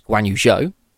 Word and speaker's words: Guan Yu Zhou: And driver number two for Alfa Romeo next season is Guan Yu Zhou. – Oh, you said it really good. I could not say it. – Look at that. Look, three for three on pronunciation.